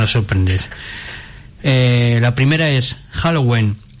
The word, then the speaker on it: Halloween: Halloween, vale.